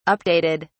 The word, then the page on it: updated: British English